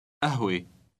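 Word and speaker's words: قهوة: Note that the first letter is pronounced a rather than ka. – قهوه قهوه Read word number 24.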